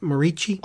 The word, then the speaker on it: Moreci: Written by Michael Morici. That's how you say it, right? Morici? – I think so, yes. – Yes.